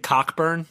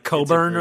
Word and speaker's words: Cockburn: They're trying to tell me his name isn't Cockburn. – Yeah. – It's like Coburn or something.